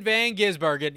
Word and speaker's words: Van Gisbergen: Shane Van Gisbergen. Yes, that's how you say his name. It's not that freaking hard. Jeez.